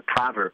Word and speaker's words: Prawer: And they have been victims of extreme project of ethnic cleansing that's been spelled out into law according to the Praver Plan, which is spelled P-R-A-W-E-R.